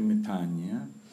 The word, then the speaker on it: metanoia: The Greek word is metanoia. Sometimes people pronounce it metanoia. It's actually metanoia, which is the same word that we use for a bow or even a full prostration.